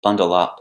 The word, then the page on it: bundle up: phrasal verb